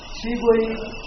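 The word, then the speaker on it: pterygoid: تریبوی پراسس که این پیش تلفظ نمیشه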